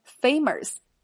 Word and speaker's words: famous: Let's try 做动词表示尝试，famous 著名的，那么也要强调一下它的发音，结尾的 o u s 发音为 us，注意没有儿化音啊，所以不要再读成 famous，而是 mus，famous。